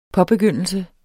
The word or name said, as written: påbegyndelse